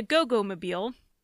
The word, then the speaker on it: Goggomobil: He admitted to owning two cars, an iridescent blue Ford Custom Line and a Goggomobil, which that is literally how you say it according to the internet.